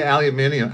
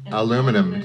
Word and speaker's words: aluminium: Well, how do you say aluminium? I mean, aluminum.